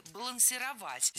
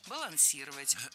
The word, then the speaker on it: балансировать: А вот настоящая ошибка нашлась в глаголе «балансировать». Здесь неправильное ударение. Переставляем его на 3-й слог и получаем правильный вариант «балансировать».